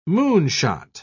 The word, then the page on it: moonshot: British English